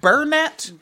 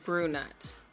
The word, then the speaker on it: brunette: Is it Burnett? – Brunette. – I've been saying it wrong for a long time. Or maybe I don't say it. I could have swore it was Burnett. – It's Brunette.